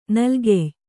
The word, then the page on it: ನಲ್ಗೆಯ್: nalgey